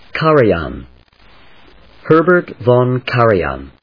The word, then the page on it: Herbert Von Karajan: Ka・ra・jan; kάːrəjὰːn ｜ kˈærə‐; von /vɑn ｜ vɔn